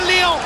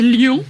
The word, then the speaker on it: Lyon: Ils disent pas Lyon, hein, en anglais, ils disent Lyon. – Oui, c'est bien, une bonne prononciation française.